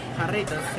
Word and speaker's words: Jarritos: And am I pronouncing it right? Jarritos? – Jarritos, yeah.